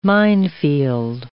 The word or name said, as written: minefield